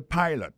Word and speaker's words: pilot: Pilot là, c'est même mot pilote là, il dit E-A-D-L-I, il écrit P-I-L-O-T. Alors, il s'est prononcé I dans cas ça. Alors, et puis, dans le mot pilot là, tout, après accent tombé sur le premier syllabe là.